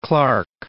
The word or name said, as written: Clarke